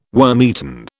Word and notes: wormeaten: British English

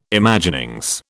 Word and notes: Imaginings: British English

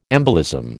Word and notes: embolism: American English

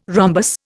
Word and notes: rhombus: Indian

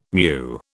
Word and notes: meu: British English

